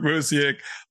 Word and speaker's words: Busiek: I never know how to say his name. – It's a Busiek. It's got that Kurt Busiek. – Kurt Busiek.